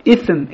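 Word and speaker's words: ифм (إثم): Допустим, слово «ифм» – это значит «грех», а слово «исм», когда язык за зубами, это значит «имя».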